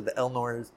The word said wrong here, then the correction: Ehlers Danlos: Oh, Ehlers Danlos.